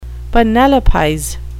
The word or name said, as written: penelopize